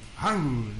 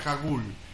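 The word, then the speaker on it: Hangul (한글): Hangul, Hangul, no pronuncia Hagul, Hagul, es H-A-N-G-U-L y se pronuncia Hangul, como una H la G. Y la N, ¿no? Hangul. Es más, hablas con más con la nariz que con otra cosa.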